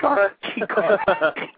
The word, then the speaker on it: Chikara: Isn't he one of the Chikara guys? Is that how you say it? – Chikara.